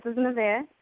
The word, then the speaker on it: Nevaeh: This is Nevaeh. – Hey, and I'm saying your name right? – Yes, you're saying it correct.